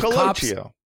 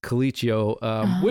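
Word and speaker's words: Colicchio: And in this episode, he pronounces it himself as Coliccio, which is... incorrect pronunciation, but as we know from me, we Italians sometimes anglicize the pronunciation of our last name to make it easier for people.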